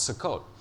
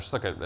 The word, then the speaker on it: Sukkot: But one of those three pilgrim festivals that I was talking about, where everyone would come to the temple, was called Sukkot, or Sukkot, as we call it in America, which is not the correct pronunciation, and it sounds very strange.